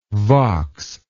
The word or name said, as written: vox